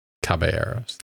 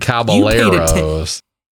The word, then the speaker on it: Caballeros: Caballeros. – Yeah, look at you. You're good. – Caballeros.